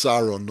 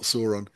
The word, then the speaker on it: Sauron: I mean, it wouldn't have been Lord of the Rings if Sauron, I only learnt recently he's pronounced Sauron, not Sauron.